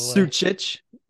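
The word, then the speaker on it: Sučić: Luka Susic. – You just mispronounced his name, probably. – Sucic.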